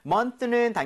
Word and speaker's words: month: Okay, so month는 당연히 개월을 얘기를 하고요. 발음할 때 months라고 하지 않는 것만 주의해 주세요. Okay, 네. so month에서 윗니, 아랫니 사이에 혀가 살짝 끼는 거지만 이렇게 나오지 않는다고 했습니다.